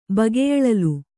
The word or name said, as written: ಬಗೆಯಳಲು